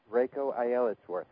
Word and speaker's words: Reiko Aylesworth: Am I saying that correctly, Rabbi? – Reiko Aylesworth.